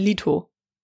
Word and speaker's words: Leto: Der wird wie Leto ausgesprochen. – Also ich habe ein Interview mit ihm gesehen, da sagt er, er heißt Lito.